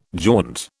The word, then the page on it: jaunt: British English